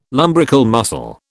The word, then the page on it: lumbrical muscle: British English